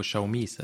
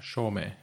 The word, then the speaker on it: Xiaomi: Det är lite som när vi var på Xiaomi's event. – Mm. – De säger ju också inte Xiaomi, utan Xiaomi.